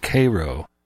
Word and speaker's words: Cairo: which is spelled like Cairo, Egypt, but is pronounced Cairo on this side of the world.